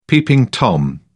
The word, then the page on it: peeping tom: British English